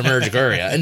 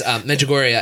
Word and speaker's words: Medjugorje: Because we're in America, so it's a Medjugorje. No, it's Medjugorje.